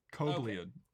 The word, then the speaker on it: Kobliad: Oh, Kobliad. Kobliad. Okay. That's how to pronounce it.